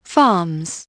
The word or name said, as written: farms